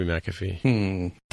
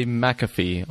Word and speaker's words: McAfee: Oh, wait, is it McAfee? How is that name pronounced? – Oh, probably McAfee. – Does say McAfee on the Wikipedia pronunciation guide.